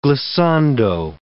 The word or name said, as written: glissando